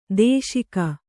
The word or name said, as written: ದೂಷಿಕೆ